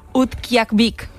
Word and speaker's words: Utqiagvik: Utqiagvik.